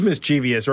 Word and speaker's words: mischievous: Because when Dave told me this, I said, wait, I think it is mischievous, right? That's an acceptable pronunciation. Yeah. It is not. – No, it's not. Mischievous.